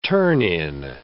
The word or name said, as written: turn-in